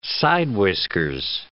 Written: side-whiskers

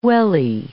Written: welly

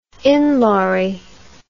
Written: inlawry

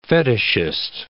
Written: fetishist

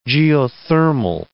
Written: geothermal

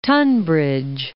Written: Royal Tunbridge Wells

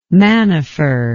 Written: main de fer

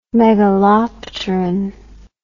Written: megalopteran

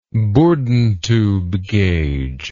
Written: bourdon tube gauge

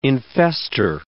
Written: infester

